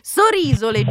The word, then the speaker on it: Sorisole: Francesca, nel frattempo ci spostiamo a Sorisole, mi hanno ripreso per la mia pronuncia sbagliata, inizialmente ho detto Sorisole, ma c'è l'ascoltatore Lia che da Bergamasco dice non potevo non notare, nonostante insomma so di sbagliare tutta una serie di altri accenti tonici nella mia pronuncia, ma va bene così. Sorisole, giusto Cristina? Pronto? – Sì, giusto, giustissimo.